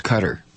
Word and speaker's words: Qatar: no, it's Qatar, Qatar.